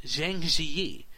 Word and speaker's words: Zhangjiajie: Well, a few days ago, the Chinese city of Zhangjiajie, you did a pretty good job pronouncing it.